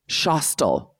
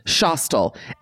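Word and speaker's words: Schostal: called Shostel. I believe. Like Eli Roth's Hostel.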